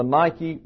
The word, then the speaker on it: Nike: N-I-K-E. And Actually, you see here, this is an eta. And an eta, pronounced in English, is like a long A. So it's nike. Nike. But the tennis shoe people have corrupted all of that, and we call it Nike.